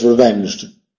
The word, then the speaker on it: revenged: ¿Es revenge o revenge? Al ser en pasado, ¿se añade otra sílaba o no? No, ¿no? So it's revenged. Se cae en el último.